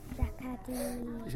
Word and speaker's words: Jacques a dit: I got how to say it in French for Jacques a dit. – Jacques a dit. – Jacques a dit.